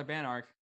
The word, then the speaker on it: Banark: People mispronounce my last name all the time. Like, I've got a lot of Banark, Barnark. I heard someone call me Barnack once.